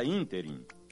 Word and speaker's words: ínterim: A palavra ínterim é proparoxítona e, portanto, com acento gráfico na antepenúltima sílaba, e não oxítona, como ouvimos por aí, ínterim. Ínterim não existe.